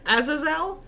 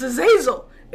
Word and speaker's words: Azazel: We were watching Sandman, and in the last episode, they mentioned a character and they called him Azazel. – Yeah, and you and it's Azazel. We're shitting ourselves like, it's Azazel.